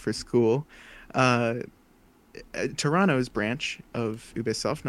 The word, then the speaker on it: Ubisoft: So Ubisoft or Ubisoft, it's pronounced differently depending on where you are and who you ask. Although the French pronunciation is what I've heard, it could be the correct one because it is originally a French company.